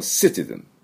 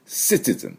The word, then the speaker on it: citizen: city じゃなくて city。でも citizen、 citizen っていうふうに発音しなければいけません。ね、最初の音、す、す。ね、どちらかというと、すの音です。